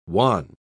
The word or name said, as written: wan